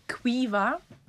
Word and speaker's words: Caoimhe: Oder Quiva. Also man denkt so Q, U, E, irgendwie sowas. Absolut fehl daneben. – Keins da, also davon also ist gar nicht. – Quiva, Quiva, der Name Quiva wird C, A, O, I, M, H, E, glaube ich, geschrieben.